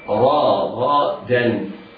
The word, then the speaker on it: رغدا: اسمع مرة ثانية، رغدا. الراء راء مفتوحة حكمها التفخيم، والغين مفتوحة حكمها التفخيم، بعدها الدال من حروف الاستفال فيكون حكمها الترقيق.